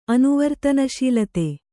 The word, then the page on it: ಅನುವರ್ತನಶೀಲತೆ: anuvartanasīlate